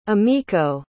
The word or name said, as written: emiko